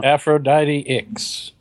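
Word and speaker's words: Aphrodite IX: You can check out a bunch of reviews, including the new Aphrodite 9 number 3, right? Is that what people want to check out? – Aphrodite X is how that's pronounced, I believe.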